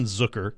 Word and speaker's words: Zucker: Jason Zucker, which we have been told. – Rhymes with? – Hooker.